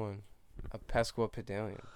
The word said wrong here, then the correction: Sesquipedalian: Sesquipedalian. My apologies. I said pesk.